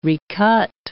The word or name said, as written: recut